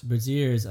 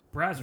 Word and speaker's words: Brazzers: How do you pronounce that website for porn called Braziers? – Braziers. I believe it's Brazier. – What is that? – Brazzer. I've seen that. It's just browsers. Brazzers. – Oh, Brazzers. That sounds more... Okay, Brazzers. All right, because I'm like...